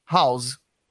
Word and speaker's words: house: a pronúncia é House. O S é com som de S, não é com som de Z.